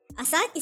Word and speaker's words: Asake: Asake, is that how you say it? – Asake.